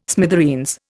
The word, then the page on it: smithereens: Indian